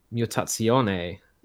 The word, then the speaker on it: Mutazione: Because we skipped a week, so I didn't get to finish talking about Mutazione either. – Oh, yeah, she found out how to pronounce it? – Yeah, I think it's just the Italian-style pronunciation, apparently.